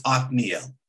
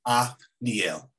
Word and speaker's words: Othniel: So it's Othniel. – Othniel.